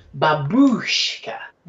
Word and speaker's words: babushka: It's a second syllable, that babushka.